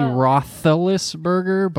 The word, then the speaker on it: Roethlisberger: It's actually Roethlisberger, but who was the other one? – No, it isn't.